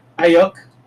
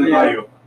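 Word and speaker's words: Aiyuk: How do you say that name? – Ayuk. – Ayuk? – Brandon Ayuk.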